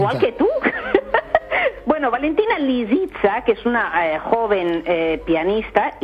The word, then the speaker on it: Lisitsa: Lo he dicho bien. – No, lo has dicho, lo has dicho fatal. – A ver cómo es. – Pues hombre, Lisitsa. – ¿Lisitsa? – Lisitsa. Lisitsa, con S.